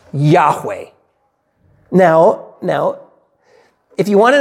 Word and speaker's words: Yahweh: And our best guess, and I think a very reasonable transliteration would be that it's pronounced Yahweh.